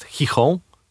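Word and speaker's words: Gijón: Są dwa takie miasta, nie za duże, w Asturii jedno jest Oviedo, a drugie jest Chichon. Pisze się to Gion.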